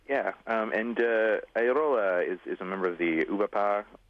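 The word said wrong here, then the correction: OuBaPo: Am I saying that right, Peter? – UBAPO. – UBAPO organization, the comics formalists that people like Matt Madden here in North America sort of belong to.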